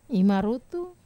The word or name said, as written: marutu